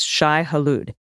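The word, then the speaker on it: Shai-Hulud: Shai Halud is pronounced Shai Halud.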